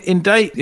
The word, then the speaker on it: indict: I mean, indict is kind of an interesting word, you know, interesting to word people. The question is, why do we not pronounce the C?